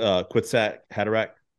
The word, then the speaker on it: Kwisatz Haderach: Do you pronounce it Kwisatz Haderach? That's what I say. – Yeah, I think so.